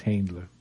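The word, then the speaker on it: Händler: And it's the inaugural Handler Awards, H-A with a little 2 dots over the A, N-D-L-E-R. – That's pronounced Handler.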